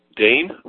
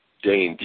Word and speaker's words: Dane: Dane? Dane, D-A-N-E.